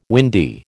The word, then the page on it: windy: American English